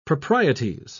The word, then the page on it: proprieties: British English